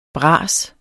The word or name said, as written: bras